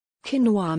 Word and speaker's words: quinoa: Quinoa may have caused a number of heated debates regarding its pronunciation, which, by the way, is quinoa, but don't let that put you off, as quinoa is considered one of the healthiest ingredients on the planet.